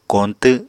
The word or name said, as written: koñtw